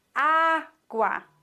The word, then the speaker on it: agua: Agua ist ein spanisches Nomen, das auf der ersten Silbe betont wird. Agua. Agua. Man sagt nicht agua, sondern man sagt agua.